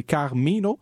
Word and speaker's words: Carminho: Elle s'appelle, puis là je veux bien le prononcer, c'est Carmino, donc C-A-R-M-I-N-H-O.